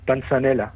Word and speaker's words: panzanella: Bueno, pues hoy tenemos una receta de, a ver si lo digo bien, panzanella o panchanella, no sé cómo se dice. Una ensalada italiana. – Eso, panzanella. – Panzanella. – Más o menos, creo que se dice así.